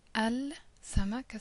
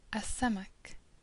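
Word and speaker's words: a samak (السمك): So instead of saying L samak as we've done up until now, we say a samak, a nur, a shajara, a tayyar, a dabit.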